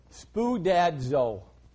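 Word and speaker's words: spoodadzo (σπουδάζω): The word is spoodadzo. S-P-O-O. D-A-D-Z-O in the Greek. Hallelujah. And if you want good pronunciation, you have to ask my buddy Alton Valentini. But that's the way I pronounce it.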